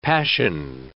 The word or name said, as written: passion